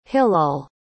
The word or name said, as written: hilol